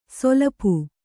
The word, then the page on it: ಸೊಲಪು: solapu